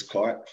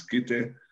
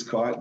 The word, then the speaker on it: Kite: And my last name is Kite, for the record, because some of the Polynesians think it's the Tongan pronunciation, which is Kite. – Okay, but it's actually Kite. – It is Kite, the English spelling and pronunciation,